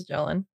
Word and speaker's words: Magellan: Melly Jelly? – I'm pretty sure it's Magellan.